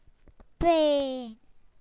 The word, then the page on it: pain: British English